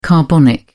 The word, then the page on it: carbonic: British English